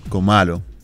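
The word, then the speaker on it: Cómalo: ¿Así que se pronuncia? ¿Comalo? – Comalo. – Ajá. Yo juraba que era como de... Como vi que era una tilde, Comalo. Pero como tú quieras.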